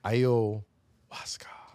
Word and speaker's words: ayahuasca: Ayahuasca.